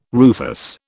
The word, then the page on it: rufous: British English